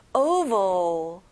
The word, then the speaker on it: oval: 자, V 발음을 정확하게 해주세요. oval.